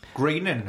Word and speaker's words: Groening: Futurama, and how to properly pronounce his name. Is it Groening? Is it Groening? – I have no idea. I never even considered that. – Groening is officially...